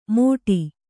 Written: ಮೋಟಿ

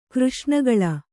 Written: ಕೃಷ್ಣಗಳ

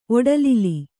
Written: ಒಡಲಿಲಿ

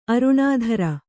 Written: ಅರುಣಾಧರೆ

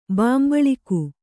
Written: ಬಾಂಬಳಿಕು

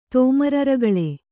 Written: ತೋಮರರಗಳೆ

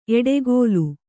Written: ಎಡೆಗೋಲು

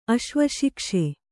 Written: ಅಶ್ವಶಿಕ್ಷೆ

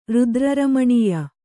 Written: ರುದ್ರರಮಣೀಯ

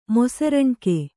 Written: ಮೊಸರಣ್ಕೆ

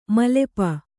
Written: ಮೈಲಾಪ

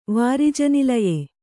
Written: ವಾರಿಜನಿಲಯೆ

